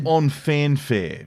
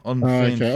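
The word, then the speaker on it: En fanfare: Is it on Fanfare? Is that how you say it? – Well, I would say on fanfare, Mr. Lee. On fanfare. – Okay. I thought it would have more pronunciation on, or something. You know, I'm just saying on fanfare.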